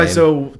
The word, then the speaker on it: Wiseau: Tommy Wiseau, or however you say his name. – Wiseau, Tommy.